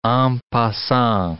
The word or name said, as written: en passant